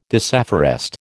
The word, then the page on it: disafforest: American English